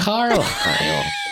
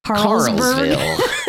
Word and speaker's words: Carlisle: Carlisle. There is a silent S in the name. I'll give you that. – No, I was like mixing up Harrisburg and all. – Carlsville.